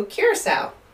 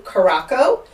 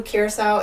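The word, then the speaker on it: curaçao: I used to pronounce it blue caraco until, you know, an older friend who was also a bartender was like, you know that's not how you say it, right?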